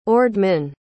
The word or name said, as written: ordman